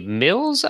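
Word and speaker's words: Mylz: Uh, one by Mills. I'll say the name is pronounced. It's M Y L Z.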